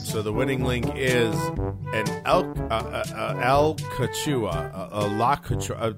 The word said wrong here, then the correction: Alachua: I can never pronounce half of the freaking counties in the state of Florida. – Alachua. – Let's go with Alachua.